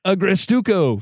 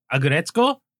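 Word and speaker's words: Aggretsuko: How do you say it? – Agretzko? – Agretzko? There we go.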